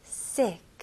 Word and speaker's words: sick: 이거는 이 발음도 아니고 에 발음도 아니고 중간 발음으로 에. 에. 네, 제 입술 길게 이렇게 됐잖아요. 근데 이까지는 아니고 에. 에. 좋아요. 그래서 여기서는 sick. sick. sick.